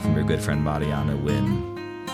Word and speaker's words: Nguyen: Whoops, I've always, what? Pronounced Win. I've been saying that wrong my whole life. Mariana Win. – Nguyen. – Yeah. Win. See, they put the pronunciation on there and I mess it up anyway.